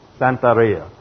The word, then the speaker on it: Santeria: Santaria. Santeria is a combination of Catholicism and voodoo.